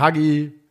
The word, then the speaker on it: Hagi: Also man muss sagen, Hagi, spricht man das Hagi oder Haji? – Haji.